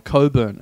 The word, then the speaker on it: Cockburn: Well, I think like Cockburn or Coburn, as you would pronounce. Cockburn.